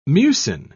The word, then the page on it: mucin: British English